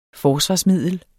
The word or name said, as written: forsvarsmiddel